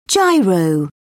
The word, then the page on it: gyro: British English